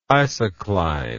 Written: isocline

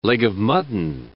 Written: leg-of-mutton

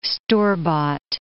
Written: store-bought

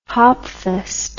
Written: hopfest

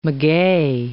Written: maguey

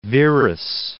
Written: Verus

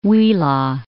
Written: Huila

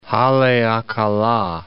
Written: Haleakala Crater

